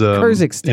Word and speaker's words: Kyrgyzstan: Kyrgyzstan? Am I saying it right? Kyrgyzstan? – Yeah, we can hop off to Asia and then come back. But yeah, Kyrgyzstan is Kyrgyzstan.